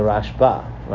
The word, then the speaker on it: Rashba: So the Rashba, right? We lean in on the second syllable.